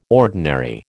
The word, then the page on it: ordinary: American English